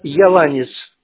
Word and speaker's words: Еланец: Да, Еланец, а не Еланец. На А ударение.